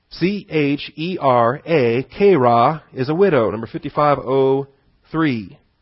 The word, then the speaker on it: chera: Second vocabulary, point B, is the kera. C-H-E-R-A, kera. That's the Greek chi, C-H-I. The long E is the eta. The rho is that P-looking thing. And then the alpha.